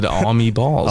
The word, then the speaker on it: AAUWMEBALLZ: How would you pronounce that name? – I think you probably got it like I would pronounce it, Almeballs.